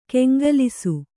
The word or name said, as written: ಕೆಂಗಲಿಸು